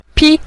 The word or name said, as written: pi5